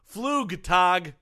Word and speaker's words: Flugtag: Nope. Flugtag, as in German.